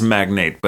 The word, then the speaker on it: magnate: I think it's magnate, but it doesn't magnate. Come up in magnet. Magnate. Kind of a magnet for things, but magnate.